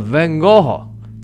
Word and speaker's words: Van Gogh: 听陈丹青先生在局部里边讲，应该叫 Van Gogh 啊，有一个尾音。那么 Van Gogh。